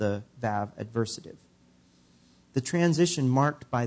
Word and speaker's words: vav: vav adversative. Vav is, in fact, the sixth letter in the Hebrew alphabet. Some people pronounce it waw, like W-A-W, waw.